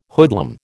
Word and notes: hoodlum: American English

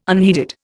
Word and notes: unheeded: Indian

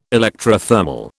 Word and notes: electrothermal: British English

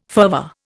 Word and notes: fervour: Indian

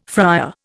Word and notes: fryer: Indian

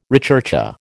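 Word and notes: recherché: American English

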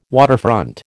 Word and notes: waterfront: American English

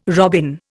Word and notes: robin: Indian

